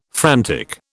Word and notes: frantic: British English

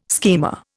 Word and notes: schema: Indian

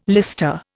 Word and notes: lister: Indian

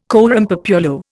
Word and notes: coram populo: Indian

